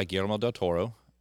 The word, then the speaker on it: Guillermo del Toro: Directed by Guillermo del Toro. He directed like the Hellboy movies. – Great pronunciation. – Thank you. – Well done. – Yeah. I didn't really trill the Guillermo though.